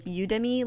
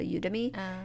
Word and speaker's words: Udemy: There's one called, I don't know how to pronounce it, but it's, like Udemy, like, U, like, university, and then, like, the end of academy, so Udemy.